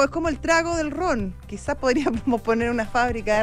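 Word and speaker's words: Rum: Bueno, es ron, se pronuncia.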